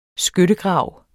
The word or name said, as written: skyttegrav